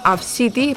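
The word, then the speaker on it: Abcde: La progenitora va explicar que la xiqueta es diu ABCD, però el seu nom original es pronuncia com Avcity.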